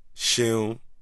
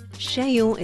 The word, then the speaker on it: Seun: Sheun. – Sheun. – Yes, good enough for me. – I'm going to go for better than good enough by the end of this interview. – Sheun is spelled S-E-U-N.